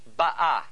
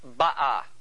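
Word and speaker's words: Ba'ah (בָּאָה): B-A-A-H. Ba'ah. It is not pronounced Ba'ah. It's Ba'ah. The accent in this particular case, the rule is usually that the accent is on the first syllable. But in this case, the accent is on the second syllable. That's right. The ah. It's ba'ah. Okay? If you put the accent on the first syllable, it would give it past tense. If you put the accent on the second syllable, it makes it present tense.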